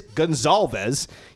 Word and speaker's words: Gonsalves: I'm like, oh, Zaire just mispronounced his name. Come on. What a way for the guy to start his Indianapolis Colts career, Zaire Franklin mispronouncing his name on stage. Well, I'm wrong, because it is Gonsalves.